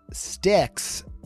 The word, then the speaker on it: Styx: And Styx rhymes with Wix.